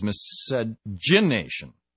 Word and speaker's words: miscegenation: This is the last one, said it's miscegenation, with the emphasis on gin.